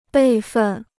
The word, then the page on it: 备份: bèi fèn